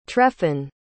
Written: treffen

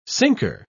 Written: sinker

